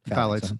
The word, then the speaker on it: phthalates: So I was listening to this podcast about a month or two ago, and they had a doctor on, and they're called phthalates. Is that the pronunciation? Phthalates. Mm-hmm.